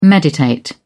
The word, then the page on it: meditate: British English